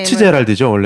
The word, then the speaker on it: Fitzgerald: 피츠제랄드죠 원래 이제.